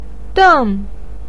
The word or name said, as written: den4